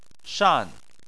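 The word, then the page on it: shan: tone 4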